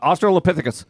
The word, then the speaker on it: Australopithecus: How do you say it? Australopithecus? – Now you fucked me up. Australopithecus.